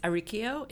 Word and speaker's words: Arricchio: It's Arricchio. It rhymes with Radicchio or Pinocchio, so a hard K sound.